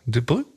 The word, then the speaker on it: Durbuy: Ich habe überhaupt keine Dur Durbai. D-U-R-B-U-Y schreibt man den. Dürbü, Dürbü vielleicht. Ja, Dürbü.